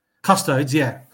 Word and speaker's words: Custodes: Custodes. Am I pronouncing it right? Custodes? – Custodes, yeah.